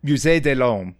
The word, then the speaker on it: Musée de l'Homme: She joins the Musée de l'Homme, which I did not pronounce right, but is like M-U-S-E-E-D-E-L-H-O-M-M-E. And evidently it's a big part of the French. – You honestly got pretty close. – How do you pronounce it? Do you know? – Musée de l'Homme. – Okay, good. That's far prettier than what I said.